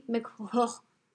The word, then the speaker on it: McCrory: Another fun fact, Helen McCrory. – McCrory. – That's the official pronunciation.